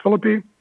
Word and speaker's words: Phillippe: Deacon, I think it's pronounced Philippi.